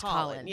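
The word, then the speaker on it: Khalen: I thought it was Kalen because it's K-H-A-L-E-N, but it's Colin. – It's pronounced Colin.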